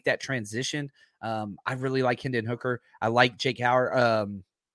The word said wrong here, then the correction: Jake Haener: I pronounced his name wrong. But Jake Haener? – Yeah, Haener, the Florida State, or Florida State, Fresno State.